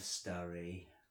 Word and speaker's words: Sturry: Welcome to the audio guide of this tour of the town of Sturry, pronounced to rhyme with slurry.